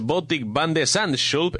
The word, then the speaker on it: Botik van de Zandschulp: Botik van de Zandschulp.